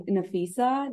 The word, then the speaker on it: Nafeesa: I'm your host, and today I'm here with Nafeesa.